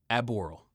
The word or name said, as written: Aboral